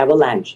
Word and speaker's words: avalanche: avalanche นะฮะถึงแม้ว่ามันจะออกเสียง CHE ตอนท้ายแต่ว่ามันมีแต่มันไม่ออกเสียงเนาะ AVALANCHE คำนี้แปลว่าอะไรครับ